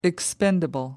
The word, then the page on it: expendable: American English